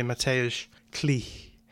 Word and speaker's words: Mateusz Klich: I should say Mateusz Klich, because that's how you say it properly.